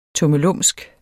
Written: tummelumsk

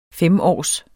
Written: femårs

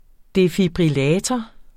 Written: defibrillator

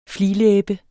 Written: fliglæbe

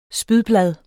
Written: spydblad

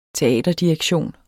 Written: teaterdirektion

